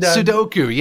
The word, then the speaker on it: Sudoku: And I use the analogy of Sukadu, Sudoku. Did I say that right? I don't know. – And, Sudoku. Yeah, no, that's 100%.